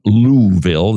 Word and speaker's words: Louisville: He teaches ethics at Southern Seminary in Louisville. That's how you say it if you're visiting the South.